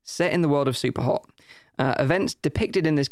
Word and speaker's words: Superhot: Before you dive deeper down the rabbit hole, someone says you should pronounce Superhot the way it should be pronounced.